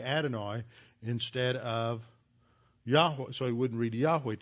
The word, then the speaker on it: Yahweh: That last syllable is the first syllable in the name of God. The second seems to be pronounced like weh, so it'd be Yahweh.